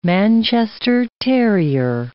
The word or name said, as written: Manchester terrier